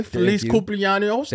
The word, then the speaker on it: Feliz cumpleaños: Feliz cumpleaños. That's how they say it, right? – Yep, that is how they say it.